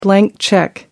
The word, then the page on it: blank cheque: American English